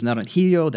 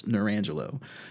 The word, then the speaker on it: Narangelo: Someone told me that it was Naranjillo. That's how it's pronounced in Spanish, but we actually have, like, I guess, an Americanized version of it, so it's Narangelo.